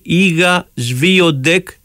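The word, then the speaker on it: Iga Świątek: Iga Swiatek, έτσι προφέρεται το όνομά της.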